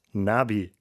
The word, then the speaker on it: Nabi: In der Sprache des Alten Testaments im Hebräischen heißt Prophet übersetzt Nabi. Das wird fast genauso ausgesprochen wie die Kurzform Navi für die Navigationsgeräte im Auto etwa.